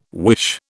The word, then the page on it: wish: British English